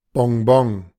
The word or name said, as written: Bonbon